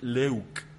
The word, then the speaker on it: leuk: que es leuk, e L-E-U-K, leuk que en todos los idiomas indoeuropeos,